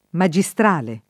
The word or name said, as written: magistrale